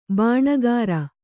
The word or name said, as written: ಬಣಕಾರ